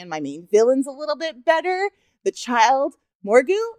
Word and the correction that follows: Grogu: Mor- no, Grogu. Grogu. – Grogu with that hard M.